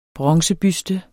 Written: bronzebuste

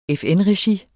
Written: FN-regi

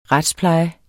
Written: retspleje

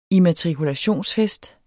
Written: immatrikulationsfest

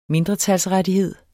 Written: mindretalsrettighed

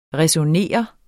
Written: ræsonnere

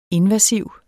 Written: invasiv

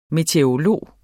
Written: meteorolog

